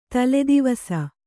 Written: ತಲೆದೆವಸ

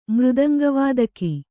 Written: ಮೃದಂಗವಾದಕಿ

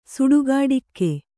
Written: ಸುಡುಗಾಡಿಕ್ಕೆ

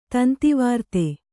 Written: ತಂತಿವಾರ್ತೆ

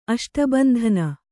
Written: ಅಷ್ಟಬಂಧನ

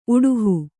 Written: ಉಡುಹು

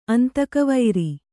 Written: ಅಂತಕವೈರಿ

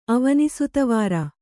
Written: ಅವನಿಸುತವಾರ

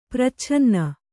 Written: ಪರಿಚ್ಛಿನ್ನ

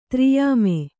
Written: ತ್ರಿಯಾಮೆ

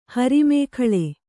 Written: ಹರಿಮೇಖಳೆ

